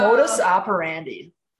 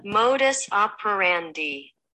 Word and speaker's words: modus operandi: Ah, I was right. Modus operandi. – I'm going to put it on slow. – Here we go. One more time. – Modus operandi. – That's how you pronounce the episode.